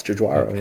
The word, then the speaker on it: Giugiaro: Giugiaro, mm-hmm. is that how you say his name? Uh, yeah, I guess Giugiaro.